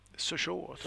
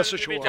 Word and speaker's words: Sochaux: Så var det väl så här att vi blev av med Charlie Davis till franska show. – Sochaux. Kommer nog bröderna Bäckström ringa in och klaga, men jag skulle säga Sochaux. – Ja, men det är uttalet rätt.